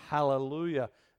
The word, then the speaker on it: Hallelujah: It's Hal, it's a huh, because in the Greek language, there's these little duvalakis that are grammatical little markings that give you the pronunciation of the huh. So it is Hallelujah.